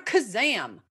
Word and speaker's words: Khozam: So my name is Barbara Kazam. – Love it. – And my last name is spelled K-H-O-Z-A-M.